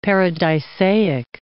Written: paradisaic

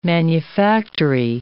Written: manufactory

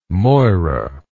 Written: moira

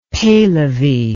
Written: Pehlevi

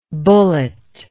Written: bulletlike

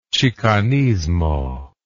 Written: chicanismo

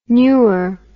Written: neur

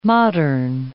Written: modern

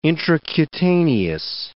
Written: intracutaneous

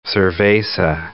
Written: cerveza

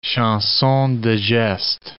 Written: chanson de geste

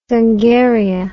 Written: Zungaria

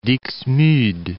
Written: Dixmude

